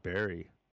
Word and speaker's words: Bury: Why is it spelled Bury, but we actually pronounce it Barry? – Why do you cook bacon and bake cookies?